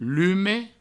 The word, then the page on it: lume: provençal